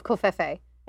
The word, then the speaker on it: Covfefe: Another Jonathan says, "Dear Master of Covfefe." Do I say that right? – You're saying it right.